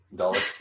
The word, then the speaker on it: Dulles: Dulles, yeah.